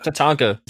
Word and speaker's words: Tatanka: Who was better, Razor Ramon, Doink the Clown, or Tanaka? Am I saying that right? – Yeah. – Tatanka.